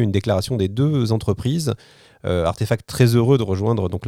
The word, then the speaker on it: RTFKT: société française Artefact qui s'écrit RTFKT. Et Artefact, ils font quoi?